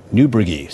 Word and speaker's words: Niebrugge: Mispronounced the name yesterday. It's a tough one. Niebrugge.